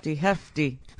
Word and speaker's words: Hefti: ...is Neil Hefti Jr. Hefti. – It's spelled differently. Oh. H-E-F-T-I. But pronounced the same.